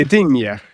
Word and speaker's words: Gdynia: What is it? Gdynia or something? – Gdynia. – Oh, out of the blue, I get it right.